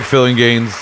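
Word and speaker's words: Phillinganes: Greg Filingaines.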